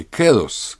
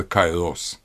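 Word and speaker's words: кэрос (καιρός): Надлежащее время, священное время ритуала – это то, что греки называли словом по-гречески, на самом деле, «кэрос», «кэрос», но у нас обычно произносится на Эразмов манер «кайрос», надлежащее, подходящее.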